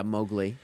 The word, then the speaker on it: Mowgli: Yeah, Mowgli.